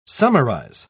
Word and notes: summarise: British English

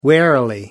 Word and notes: warily: British English

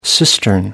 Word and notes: cistern: American English

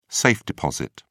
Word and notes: safe-deposit: British English

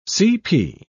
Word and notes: cp.: British English

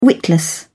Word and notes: witless: British English

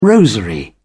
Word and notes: rosary: British English

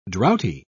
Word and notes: droughty: British English

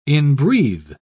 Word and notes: inbreathe: British English

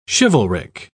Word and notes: chivalric: British English

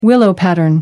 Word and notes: willow-pattern: American English

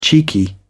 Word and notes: cheeky: British English

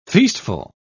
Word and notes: feastful: British English

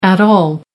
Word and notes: at all: American English

